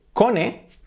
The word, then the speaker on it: cone: Pues fijaos, tenemos, con esta terminación, cone, tenemos stone, que acaba en stony, stone, y también hueso, escrito bone y se pronuncia bone. Por lo tanto, bone, stone, cone.